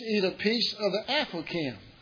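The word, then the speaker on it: afikoman: I said, how do you pronounce this word? And she said, it's aphokim.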